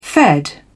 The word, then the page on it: fed: British English